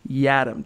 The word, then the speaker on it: Yiadom: For fans wondering, since it is a curiously spelled name, Isaac himself, I heard him do it on Saturday, pronounces it Yiadom. Think Adam with a Y.